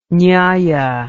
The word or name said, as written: nyaya